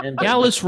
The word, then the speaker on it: Gallus: Gallus rhymes with phallus.